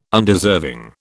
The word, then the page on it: Undeserving: British English